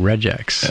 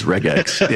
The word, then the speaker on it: regex: My entire life, I've been saying regex. – Yeah. And you've been wrong. – It's regex. Yeah. – Well, it's regex.